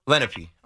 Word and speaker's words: Lenape: Lenape. – I feel like I've heard of it before. I used to know this girl at JMU that I think she went to Lenape High School.